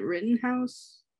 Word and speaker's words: Rittenhouse: Rittenhouse? – Yeah.